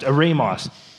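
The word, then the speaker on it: eremos (ἔρημος): Pronounced eremos.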